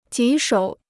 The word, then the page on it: 棘手: jí shǒu